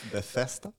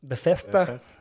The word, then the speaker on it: Bethesda: Eh, och Bethesda, jag vet inte om jag uttalar det där, Bethesda. – Bethesda. – Bethesda. Man ska läspa lite.